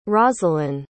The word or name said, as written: roselyn